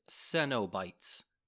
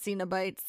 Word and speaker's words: Cenobites: I blame this all on you. It's Cenobites. – Oh, is it? – Yeah, did you not watch the second one? – I did, but I'm still pronouncing it Cenobites.